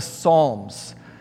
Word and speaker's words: Psalms: There's a book particularly in there called the Psalms. It has a silent P at the beginning. P-S-A-L-M. You might want to pray this one.